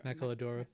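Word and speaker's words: maquiladora: I say it as Machiadora, but I don't know if it's Machiladora. – Machiladora. – Is that how you say it? Yeah.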